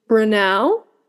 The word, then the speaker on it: Braunau: He had forced her to write it while she was in captivity, but postmarked it from, okay, so I'm sorry about this pronunciation, Braunau? – I think that's right. That sounds right.